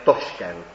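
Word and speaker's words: Toshkent: My mu říkáme tak jako Rusové Taškent, ale uzbecky se to město jmenuje Toškent. Ta výslovnost Taškent je z ruštiny a my jsme ji teda do češtiny přejali.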